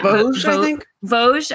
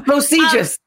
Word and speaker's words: Vosges: Vosges. – Okay. Yeah. So Vosges has. – Don't ask me of all the people. – Right. Hey, how do you say it? – No. Vosges.